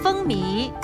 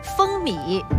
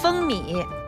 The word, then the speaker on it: 风靡: 我们一起来看第一题，以下词语的正确读音是 A 风靡， B 风靡，请作答。来看一下正确答案，B，风靡。